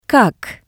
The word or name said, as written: kak